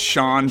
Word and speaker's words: Jon: Well, be happy you don't have my name, which is pronounced Sean but spelled J-O-N.